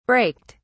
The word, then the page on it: braked: British English